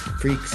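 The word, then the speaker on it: Wee Freekz: And We Freaks, that's spelled W-E-E space. F-R-E-E-K-Z. – Yes. – Freaks.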